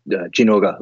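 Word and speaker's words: Zinogre: Playing squirrel electric thing, to go back to the whole series question, like my favorite overall is probably I guess in English Zinoga, in Japanese pronounced Jinoga, like the portable third.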